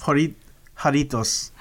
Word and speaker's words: Jarritos: Jarritos. – Jarritos.